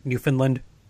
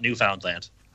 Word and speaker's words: Newfoundland: And by the way, did I say that correctly, the correct Canadian pronunciation, Newfoundland? – Yeah, it's not Newfoundland. – All right. – No, just slur that baby together.